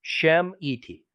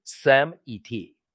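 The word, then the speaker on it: Shemite: 这个词的拼法应该是山母人 Shem et，而非山族人 Sam et。这是非犹太外邦基督徒常犯的错误，因为希伯来发音字目标中，当我们按照音一式撰写成 S 字母，可用两种方式发音，像是读作 Sheen 光泽，或是读作 Sin 看见。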